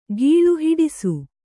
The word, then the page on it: ಗೀಳು: Kannada